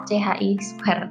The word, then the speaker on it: chi square: kumpulkan hari ini maksimal jam 11.30 semoga lebih cepat Lalu untuk pertemuan berikutnya baca dulu mengenai chi square atau CHI square. Uh, ini bacanya chi square ya, tapi saya nulisnya chi square karena memang tulisannya chi square tapi kita bacanya chi square.